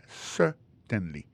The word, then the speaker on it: certainly: pour un mot certainly accent tombé sur premier syllabe là yes certainly